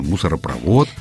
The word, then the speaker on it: мусоропровод: Но вообще в русском языке слова, которые на «вод», да, заканчиваются, произносятся с ударением на последний слог. И достаточно запомнить это одно простое правило и никогда не ошибаться. Мусоропровод, нефтепровод, воздухопровод и так далее.